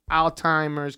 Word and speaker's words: Alzheimer's: Yeah, unless I really cut out like sodium and all that stuff, but I'm on the fast track to like Alzheimer's, cancer, Alzheimer's. Diabetes. Yeah. I said it wrong? Alzheimer's? – Yes. – Alzheimer's? What are we in Spain? Alzheimer's? What the fuck? It's Alzheimer's.